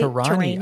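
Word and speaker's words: Torani: Tirani. Am I even saying that right? – Tirani. Oh, it's like Tirani.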